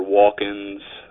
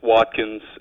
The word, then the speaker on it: Watkins: Watkins is Welsh, and it can be kind of tricky to pronounce sometimes with the T and the K right next to each other. I've heard it pronounced Watkins or Walkins, but it's just Watkins, and if you think you're going to have trouble saying my last name, you can just call me Adam.